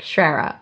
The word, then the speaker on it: Schrire: On press reach out emails, I've started being like, Schrera, pronounced, and try and write it phonetically.